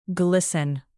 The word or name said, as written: glisten